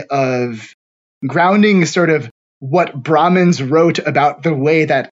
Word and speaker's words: Brahmins: So Brahmins are the priests.